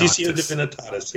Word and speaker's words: Laetitia Divinitatis: I don't know how to pronounce that. Is that right? Divinitatis. – Laetitia Divinitatis. Yep.